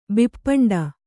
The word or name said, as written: ಬಿಪ್ಪಂಡ